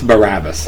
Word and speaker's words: Barabbas: A, Barabbas. Barabbas. I always freaking mispronounce this. Okay. C, Barnabas. C, Bartholomew. Bartholomew. D, Barak. – It's Barabbas.